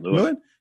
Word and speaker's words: Lewin: Did I say it right? – Lewin? – Yeah. Yes.